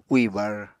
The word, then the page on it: úì bar: Apatani